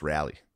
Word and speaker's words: Raleigh: It's pronounced Raleigh. – Everyone gave me shit for it. It's Raleigh, apparently. – No, it's Raleigh.